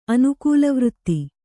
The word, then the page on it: ಅನುಕೂಲವೃತ್ತಿ: anukūlavṛtti